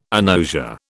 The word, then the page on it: agnosia: British English